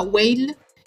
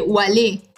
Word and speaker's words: Wale: Pero sabes que yo siempre pensé que se, que se pronunciaba Whale y no se pronuncia Whale. La escuché ella nombrándolo en entrevistas y se dice Wale. Wale. – Se pronuncia Wale, tipo nada que ver.